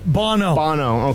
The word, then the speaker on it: Bono: But Bono? Did I say that right? – Bono.